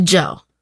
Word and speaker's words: Dzo: Eventually, he changed his stage name from Cool Cool Cool to Joe. – It is spelled D-Z-O, but is pronounced Joe.